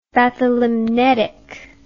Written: bathylimnetic

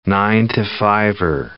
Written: nine-to-fiver

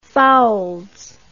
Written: foulds

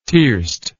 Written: tierced

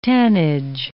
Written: tannage

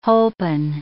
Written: holpen

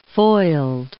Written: foiled

